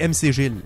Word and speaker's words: MC Gilles: Tout ce que tu as à te rappeler, c'est MC Gilles. Ça, c'est mon nom. Ça s'écrit M-C-G-I-L-L-E-S.